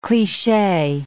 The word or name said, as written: cliche